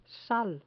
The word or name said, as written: sal